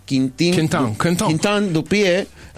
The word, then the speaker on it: Quentin Dupieux: La primera de ellas es de Quintin Dupieux, así se pronuncia.